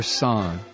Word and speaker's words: sans: You get the right answer, you win a one-year subscription to Malloy Podcasts, sans the commercials. Is it sans or song? Song. You don't pronounce the S, right?